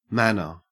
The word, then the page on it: manor: Australian English